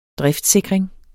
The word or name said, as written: driftssikring